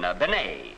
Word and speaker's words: Bennett: Now, sometimes they give her name the French pronunciation, Benet. And Benet and Benny sound very much alike.